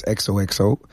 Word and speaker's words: XOXO: Bueno, la verdad es que lo dices perfecto, ¿vale? El nombre es XOXO.